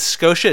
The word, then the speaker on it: Skeauxsha: The band name is Scotia. It's spelled S-K-E-A-U-X-S-H-A.